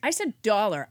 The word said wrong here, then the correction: dolor: I believe it's dolor.